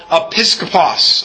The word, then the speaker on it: episkopos: But the actual Greek word doesn't quite sound like bishop. The actual Greek word is a Greek word called episkopos. Episkopos.